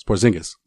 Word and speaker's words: Porzingis: How am I saying it wrong? – It's Porzingis.